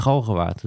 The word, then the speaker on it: Galgenwaard: Ja, het kan spoken in de galgenwaard. Dat is alweer lang geleden, maar het klinkt wel altijd goed. Hoe spreek je dat op zijn plat Utrechts uit? Galgenwaard. Galgenwaard.